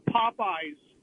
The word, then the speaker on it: Popeyes: Instead of Popeyes, I've been calling it Pope Yes. – Pope Yes. – That's what my son does. – Pope really? Yes. – Yeah. – He calls it Pope Yes? – Because that's the way it's spelled.